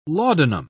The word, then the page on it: laudanum: British English